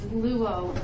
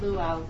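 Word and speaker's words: luo (λύω): And the first word I want to look at in the New Testament is pronounced apolutrosis, A-P-O-L-U-T-R-O-S-I-S. Apolutrosis. And the verb is luo. Not luau, luo.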